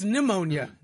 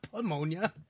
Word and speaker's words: pneumonia: Why do you say the P? The P is silent. It's pneumonia. It's not pneumonia.